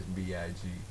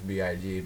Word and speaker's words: B.I.G.: Notorious B.I.G. Now, see, I know that it's B.I.G. because I know what I'm talking about. It's not Notorious Big.